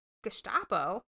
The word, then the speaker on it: Gestapo: G-E-S-T-A-P-O? – The Gestapo. – Gestapo? – Yeah. – Gestapo. Okay, cool. – It's the, What is that? – It's the Nazi German police. – Oh, interesting. Okay. – Gestapo.